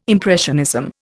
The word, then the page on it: impressionism: Indian